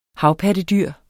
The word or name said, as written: havpattedyr